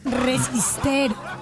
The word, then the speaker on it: resistero: ¿Resisterio? No, ¿cómo se dice? – Resistero. – Resistero del sol.